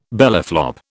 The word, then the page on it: bellyflop: British English